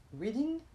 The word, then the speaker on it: Reading: And so then anyway, after my trip to Nantucket, I went to Boston in a little town which is called Reading. Reading.